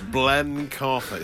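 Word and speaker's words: Blencathra: Oh, it's just not even Ben. It's Blen. – Blen Carfa.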